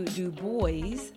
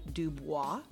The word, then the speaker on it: Du Bois: We ended up going to Du Bois, Pennsylvania, which that's how it's pronounced because a local corrected me. It's D-U space B-O-I-S, and I said Du Bois because I took French all in high school, and that's how you would pronounce it. But our server at Pizza Hut corrected me and said, here we call it Du Bois.